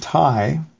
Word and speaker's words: Thai: And then more recently we've got a guy named Ty, spelled like T-H-A-I, like the country.